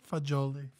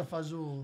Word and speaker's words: fagioli: Fagoli? – Fagioli. – Fagioli. Fagioli. Oh, gee, okay. – Pasta fazool.